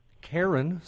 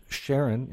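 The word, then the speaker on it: Charon: Karen, sometimes pronounced Sharon, is the ferryman responsible for shepherding souls across what river in Greek mythology? – The Rubicon?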